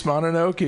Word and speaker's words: Mononoke: Princess Mononoke in the house.